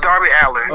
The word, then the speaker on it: Darby Allin: It's Darby Allin.